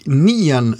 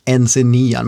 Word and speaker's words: Nc'nean: Alltså N-C-N-E-A-N. NC9, men nej, Nien ska det uttalas.